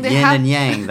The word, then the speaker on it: yin and yang: Yin and yang. That I say with my terrible American pronunciation, but it should be yin and yang.